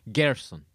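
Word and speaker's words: Gersón Rosas: Pobo Gersonrosas. That was a bad pronunciation. – Gerson. – Thank you.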